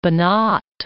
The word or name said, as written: Banat